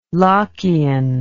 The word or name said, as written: lockean